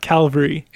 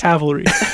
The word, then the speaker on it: Cavalry: Are we saying that right, by the way? Cavalry? – I believe we are saying it right. – I believe we all got a bit of a call out on whether social media. They posted like a pronunciation video. – Cavalry and Calvary. – It's not Calvary. – Yes. – It's Cavalry. – Those are very different.